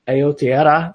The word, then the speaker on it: Aotearoa: It means in New Zealand, but it's Aotearoa.